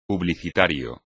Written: publicitario

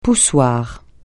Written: poussoir

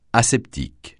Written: aseptique

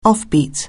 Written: offbeat

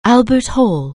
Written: Albert Hall

